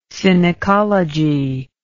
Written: synecology

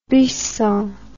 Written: buisson

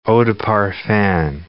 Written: eau de parfum

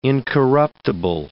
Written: incorruptible

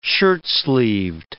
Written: shirtsleeved

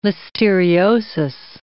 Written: listeriosis

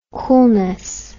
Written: coolness

